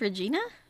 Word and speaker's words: Regina: Wait, it's not Regina? – Nope, Regina. They say that on the crown. Rhymes with vagina.